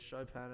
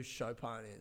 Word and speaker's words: Chopin: I'm all about people who know who Chopin is and who know that I'm not being a dick by saying it like that. I feel like an asshole for saying it correctly. Chopin, not Chopin, Chopin.